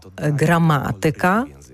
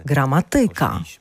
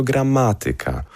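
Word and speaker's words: gramatyka: Ми говоримо граматика, а не граматика. – Тільки пошлішми. Не граматика, тільки граматика.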